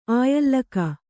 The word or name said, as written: ಆಯಲ್ಲಕ